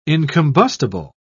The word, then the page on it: incombustible: British English